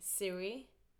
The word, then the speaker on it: Siri: And I always start by saying Siri. Mm. Like the Swedish pronunciation. – Yeah. – But then everyone says, what? Or what did you say? And then I say Siri.